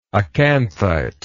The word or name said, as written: acanthite